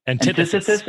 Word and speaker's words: antithesis: How do you say it? – Antithesis. – Yes, to GameStop.